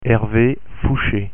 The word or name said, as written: Hervé Foucher